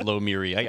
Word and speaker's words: Lomiri: On their website, they actually had the pronunciation, and it was Lomiri.